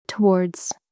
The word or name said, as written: towards